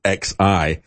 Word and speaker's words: Xi: I don't know why they put his name out as XI and pronounce it Xi.